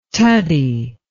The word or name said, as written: tutty